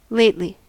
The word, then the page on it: lately: American English